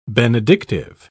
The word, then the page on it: benedictive: British English